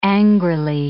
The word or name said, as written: angrily